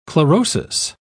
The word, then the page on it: chlorosis: British English